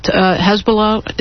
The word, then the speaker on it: Hezbollah: I was reading that Hezbollah, is that how you say it? – Yes. That's how I say it.